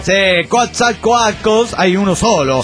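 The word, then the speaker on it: Coatzacoalcos: La primera es que se pronuncia Quatzacoalcos. Esto viene porque el programa anterior alguien les mandó saludos desde ahí y no lo supieron pronunciar.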